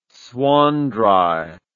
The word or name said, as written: swanndri